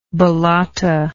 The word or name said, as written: ballata